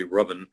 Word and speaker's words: Ruthven: spelled Ruthven. Nobody pronounces it correctly. It's actually Ruthven.